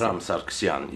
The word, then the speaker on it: Vahram Sargsyan: Und dort werden Lieder von Lilith Kerscher-Sargsyan im neuen Arrangements von Vahram Sargsyan, habe ich richtig, wie spricht man aus, Sargsyan, ist das? Vahram Sargsyan, ja.